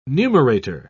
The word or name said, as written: numerator